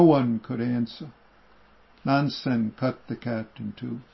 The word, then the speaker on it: Nansen: Nanchuan, also known as Nansen, at Nansen's one day, Nansen is the Japanese way of pronouncing it, the residents of the East and West Halls were fighting over a cat, quarreling over a cat.